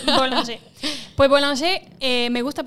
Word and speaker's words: Bollinger: Manon, dinos lentamente cómo se dice. – Bollinger. – Boulanger. – Perfecto.